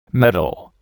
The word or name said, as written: medal